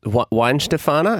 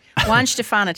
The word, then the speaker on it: Weihenstephaner: So, from a German perspective, it is Weinstephan.